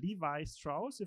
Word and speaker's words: Levi Strauss: You're presenting Levi Strauss, if I pronounce it.